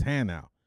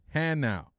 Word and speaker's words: Hanau: And he said, your bus left 2 hours ago, and it's not Hanua, it's Hanau. I'm like, no, that freaking thing says Hanua. They said, troop, be quiet, at ease. And I'm like, okay. It is, the name is pronounced Hanau.